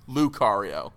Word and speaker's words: Lucario: now, I my, my, my second, my very close second though is the is Linkario and the Linkario. reason did I say it wrong? Lucario. Lucario. Whatever.